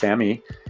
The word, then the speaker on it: SAMe: So S A M little e looks like the word same, but it's pronounced Sammy.